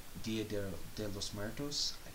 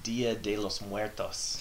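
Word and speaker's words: Día de los Muertos: Please tell me I said that right. – Nope, not even close. Oh, white boy. – Shut up. Okay, how do you say it properly? It's Dia de los Muertos.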